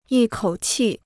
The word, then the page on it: 一口气: yī kǒu qì